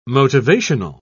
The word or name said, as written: motivational